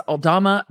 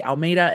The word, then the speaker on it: Aldama: It's Aldama, but I'm going to say Almeida, and I'm going to continue going with Coach Monica.